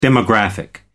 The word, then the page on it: demographic: British English